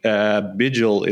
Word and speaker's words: Bijl: I have a weird name for if you're not from the Netherlands, but Freek Bijl, it's spelled like bile.